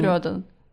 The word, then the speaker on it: Рёдан: В общем, мы поговорили с вами сейчас про соцсети, и вы знаете что процентов э, про недавний скандал с ЧВК Рёдан, да? Рёдан, по-моему, там вообще Нет, ударение. Рёдан.